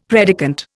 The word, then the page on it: predicant: Indian